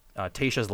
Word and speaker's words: Tayshia: Bachelor Nation was first introduced to, you said Tayshia? Tasha? – Tayshia.